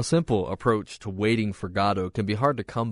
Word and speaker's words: Godot: The only thing he insists on is that it's pronounced Godot. And he said, with a twinkle in his eye and a wink at the questioner, because it sort of sounds like God.